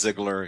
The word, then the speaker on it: Ziegler: It's like the wrestler Dolph Ziegler.